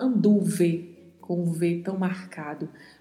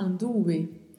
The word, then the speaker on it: anduve: Então a gente não fala anduve com o V tão marcado. A gente vai falar anduve, anduve para ficar um som entre a letra B e a letra V.